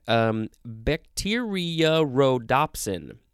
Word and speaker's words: bacteriorhodopsin: Our first word is a weird word, bacteriorhodopsin. Bacteriorhodopsin. There's a kind of a double O sound in there.